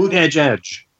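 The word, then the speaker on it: Buttigieg: Buddy, how do you say Boot Edge Edge.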